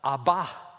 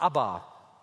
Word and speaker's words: Abba: Es heißt Amen und nicht Amen, wenn man es korrekt aussprechen möchte. im Hebräischen. Es heißt übrigens auch die Anrede, die Jesus für Gott hat, Abba und nicht Abba. Abba ist eine schwedische Popgruppe. Er erhebt also nach dem Essen diesen Kelch und dann geht dieser Kelch rum.